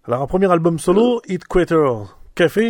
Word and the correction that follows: Headquarter Café: je ne sais pas si j'ai bien prononcé. – Oui, Headquarter Café, oui.